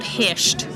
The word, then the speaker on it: Hirta: He's working on a play at the moment called Hisht, which is spelt H-I-R-T-A, Hisht, which is an island...